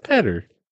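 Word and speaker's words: Pedder: I don't know how you pronounce it. It's P-E-D-D-E-R. Was crushed. It's Petter.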